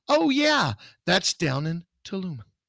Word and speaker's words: Tulum: or Tulum.